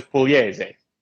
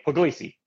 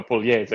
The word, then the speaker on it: Pugliese: There's no right way to say my name in English because it's an Italian state called Puglia. So okay. My name is Pugliese, but I tell everybody Pugliese.